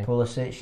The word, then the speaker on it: Pulisic: I only know like one US player, and that's Pulisic. Is that how you say his name? – Pulisic, yeah.